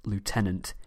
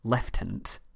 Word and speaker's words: lieutenant: In case you're not aware, in the UK we pronounce lieutenant as lieutenant. Since Lieutenant Governor is a political office, still found today, it seems to make more sense for me to go with the American pronunciation rather than the British.